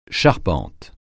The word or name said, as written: charpente